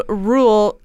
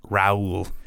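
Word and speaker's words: Reuel: It's John Ronald Rule Tolkien. – Rule. – I don't know what that is. – I thought it was Raoul. – It's spelled R-E-U-L.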